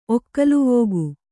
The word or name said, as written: ಒಕ್ಕಲುವೋಗು